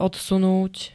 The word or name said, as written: odsunúť